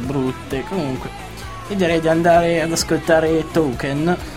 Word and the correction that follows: Taken: Detto male, però non importa, perché loro le parole le pronunciano bene più di me, anche perché sono madrelingua. Taken, One Direction.